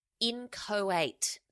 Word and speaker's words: inchoate: It is inchoate. That's I-N-C-H-O-A-T-E, inchoate.